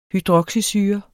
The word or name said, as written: hydroxysyre